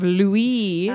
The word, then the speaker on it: Louis: Because I didn't think of it as the French pronunciation of Louis.